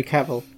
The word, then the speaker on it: Cavill: Henry Cavill. – Yeah. I keep pronouncing his name wrong. It's Cavill.